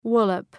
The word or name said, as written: wallop